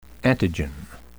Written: antigen

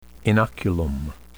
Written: inoculum

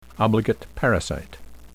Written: obligate parasite

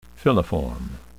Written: filiform

